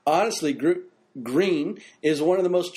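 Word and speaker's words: Gruene: This little historic city of Gruny, Texas. – What? – Gruny, Texas. – I think it's pronounced green. – Well, it's spelled Gruny. – That's because it's German.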